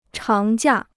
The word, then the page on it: 长假: cháng jià